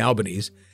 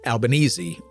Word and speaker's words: Albanese: Anthony Albanese, I was saying Albanese, last name is pronounced Albanese or Albanese.